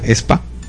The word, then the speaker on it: aespa: El primero, el grupo no se llama AESPA, de hecho se llama ESPA. – ESPA, sí, se pronuncia así, o sea, ESPA. – Se escribe AESPA y se pronuncia ESPA.